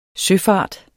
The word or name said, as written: søfart